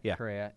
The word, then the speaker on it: Correa: Did I say that right? – Correa. – Yeah. Okay.